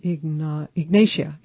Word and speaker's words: Ignatia: And Ignatia, I think I'm saying that right. Ignatia, I-G-N-A-T-I-A, Ignatia Imara, is for panic attacks, panic and restlessness.